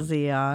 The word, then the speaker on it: Zeon: hey, Zion. And he spells it like the way I used to spell it in my uh, Z E O N great ham radio podcast.